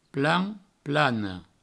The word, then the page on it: plan plana: plan plano